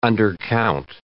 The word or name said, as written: undercount